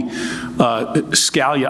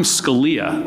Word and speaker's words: Scalia: With respect to the name, often pronounced Scalia, less so these days. But I used to tell my dad when people would ask me, when they would call me Scalia, I would say, no, I'm Scalia.